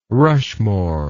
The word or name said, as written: rushmore